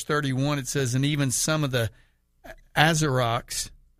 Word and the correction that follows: Asiarchs: is that how you say it? – Asiarchs, who were friends of his, talking about, these are friends of Paul.